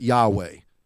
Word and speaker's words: Yahweh: But usually we pronounce it Yahweh.